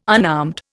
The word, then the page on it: unarmed: Indian